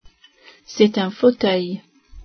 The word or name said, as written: fauteuil